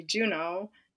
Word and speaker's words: Juneau: Because one of my friends, when I told her via text that my daughter's name's going to be Juno, she's like, how do you say that? And I was like, you know, like, capital of Alaska?